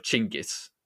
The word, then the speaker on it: Genghis: It's the Italians who brought that sort of name instead of Chinggis, and I think that's why we pronounce it. Why most people in Europe and America will pronounce it Genghis.